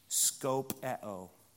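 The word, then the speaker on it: skopeo (σκοπέω): The Greek word is skopeo. What word do you hear? Scope.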